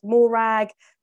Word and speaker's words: Morag: It's like rhymes with slag, right? So she must have had Morag, crag, drag, bag, nag.